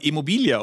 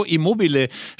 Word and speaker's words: Immobile: Ich muss mich derweil, während du guckst, über Ciro Immobile, ich habe den immer Immobilia ausgesprochen, Ciro Immobile sprechen äh, von Borussia Dortmund, der ja auch als Nachfolger von Robert Lewandowski eingekauft wurde.